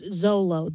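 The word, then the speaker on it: Xolo: It's so hard to pronounce. I mean, it looks like Mr. McClixifix, whatever it was from Superman comics. – Yeah, but apparently it's pronounced Zolo.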